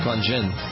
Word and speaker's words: Qui-Gon Jinn: Qui-Gon Jinn.